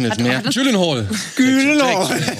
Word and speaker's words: Gyllenhaal: Gyllenhaal, Dankeschön. – Ja, das, das ist Aktionen. Ach so. that is pronounced correctly, my last name, like in Sweden and in IKEA.